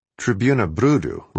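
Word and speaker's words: Trybuna Brudu: Tribuna Brudu rhymes with Tribuna Ludu, People's Tribune, the Communist Party newspaper.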